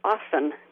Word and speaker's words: often: And I don't know exactly when or why this came about, but the word often, like O-F-T-E-N, the T is silent.